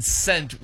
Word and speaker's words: scent: Well, you know, the so if in scent, which one is silent? I've never heard that question before. That's really good, though. – The C. – Okay. – I think it's an easy answer, though. – I'd like to say for fun, the S is silent in scent. – Well, you do have a valid point with C-E-N-T, but I think everybody's got equally value, kind of like John and Joe Ellis, you know, equally valuable points.